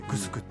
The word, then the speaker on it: 城: 沖縄城と書いてグスクってね、読むパターンよくありますけどね。